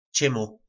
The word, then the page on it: Cymu: po nasimu